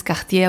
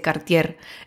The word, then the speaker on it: Cartier: que es Cartier que la última vez que dije con el acento francés Cartier a una amiga, no conocía la marca y me di cuenta que no se dice Cartier, se dice Cartier.